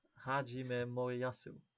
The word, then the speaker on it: Hajime Moriyasu: Hajime Moriyasu.